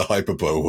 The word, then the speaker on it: hyperbole: like you've done with all the hyperbole words. – My hyperbole. – What is it? Hyperbole, thank you.